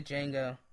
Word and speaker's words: Django: The D is silent. Yeah. I always want to say that. I always want to say that when someone says the Django. – Really? – Yeah. It's like the D silent.